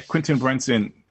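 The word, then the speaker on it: Quinta Brunson: Each character, like, the thing about it is, we know that Am I saying her name correctly? – Quentin Brunson. – Yeah, Quentin Brunson.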